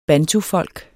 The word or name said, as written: bantufolk